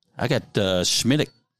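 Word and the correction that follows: Smithwick's: and Sons Ireland Red Ale. – You said it wrong. – Irish made. – Swidix. – I'm sorry. – Yeah. – Yeah. Swidix.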